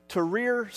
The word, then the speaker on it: Tahrir: In 2011, during the Egyptian Revolution, there was a group of Christians in, I'm going to get this right, because I was complimented after the first service that I actually pronounced this right, Tahrir Square in Cairo.